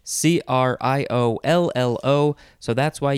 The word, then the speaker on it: criollo: Okay, so the first word in this episode is Criollo. Criollo, or you can say it Criollo without the L sound.